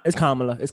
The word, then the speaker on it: Kamala: Yeah, it's Kamala.